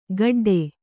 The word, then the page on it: ಕಠಡೆ: kaṭhaḍe